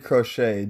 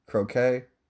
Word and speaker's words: croquet: I once played crochet down in Scottsdale. Is that how you say it? Crochet? Croquet? I think maybe it's croquet.